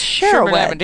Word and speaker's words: Shaerrawedd: It's Sherawed. – Ding dong. – Sherawed. – So, Sherawed.